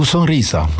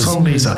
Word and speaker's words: Sonrisa: Sonrisa, ist es richtig ausgesprochen oder dreht's dem Sascha dann gleich mal die Ohrwatscheln um? – Nee, gar nicht. Nee, tu Sonrisa. Schon hast du schön gesagt. Du hast ein weiches Sonrisa.